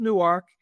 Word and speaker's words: Newark: Remember, it's pronounced Newark, N-E-W-A-R-K, Newark.